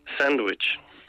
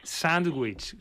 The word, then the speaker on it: Sándwich: Por cierto, ¿cómo se dice? ¿Sándwich o cómo se pronuncia? – Sándwich. – Sándwich, si hay que marcar la N ahí.